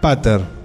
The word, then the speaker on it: putter: Yo dije put. Porque se escribe P-U-T-T. – Claro. – Me faltó la E y la R. Putter.